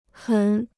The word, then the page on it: 很: hěn